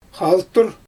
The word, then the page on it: xaltur: Xyzyl